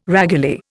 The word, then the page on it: raguly: Indian